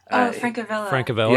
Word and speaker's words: Francavilla: I apologize if I butcher his name, but Francesco Fravella? Fravilla? – Francavilla. – Yeah, Francavilla. Yeah.